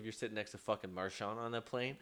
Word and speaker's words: Marchand: Just quickly, Brad Marchand.